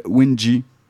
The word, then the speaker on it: Wing: Il s'appelle Wingy. Ça s'écrit Wing, comme les ailes.